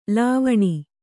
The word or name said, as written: ಲವಣಿ